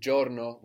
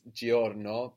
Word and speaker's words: giorno: And then giorno, which is day, spelled GIORNO. So sometimes the I in Italian won't be heard that clearly, like here. We don't really pronounce giorno, but giorno.